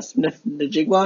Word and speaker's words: Njigba: Smith Njigba, I think is how you say his full last name.